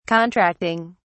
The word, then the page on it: contracting: British English